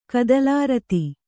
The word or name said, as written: ಕದಲಾರತಿ